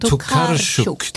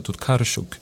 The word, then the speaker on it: Tokarczuk: Men Tokarczuk. – Trots att det är lite mjukare ljud på polska, Tokarczuk. Så hade det varit ryskt hade du varit med Tokarczuk.